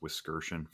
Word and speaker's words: Wiskirchen: And I'm Jake Wisk everywhere, J-A-K-E-W-I-S-K. And the last name is pronounced Wiskirchen, for those of you who are curious.